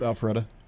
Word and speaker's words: Alpharetta: He's originally from Rochester, New York, but grew up in Alpharetta, Georgia. Did I say that right? – Yep, Alpharetta.